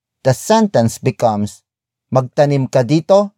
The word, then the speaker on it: dito: It is followed by dito, dito, dito. Dito is spelled as D-I-T-O.